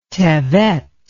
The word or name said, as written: tevet